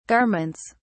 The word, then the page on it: garments: British English